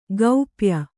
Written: ಗೋಪ್ಯ

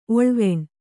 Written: ಒಳ್ವಣಿ

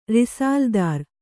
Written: ರಿಸಾಲ್ದಾರ

